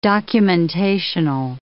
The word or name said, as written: documentational